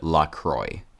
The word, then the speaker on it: La Croix: Sorry, La Croix. I forgot that the company officially pronounces it La Croix.